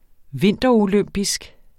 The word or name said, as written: vinterolympisk